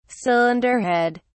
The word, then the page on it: cylinder head: British English